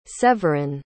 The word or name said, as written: séverin